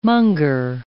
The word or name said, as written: monger